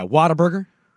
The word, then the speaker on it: Whataburger: Whataburger? – Yeah, Whataburger. – No. – Yeah, that's how they say it.